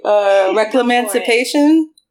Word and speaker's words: Reclamancipation: Is it, oh, can I pronounce this correctly? Reclamancipation? Is that it? – Yeah.